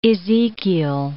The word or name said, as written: Ezekiel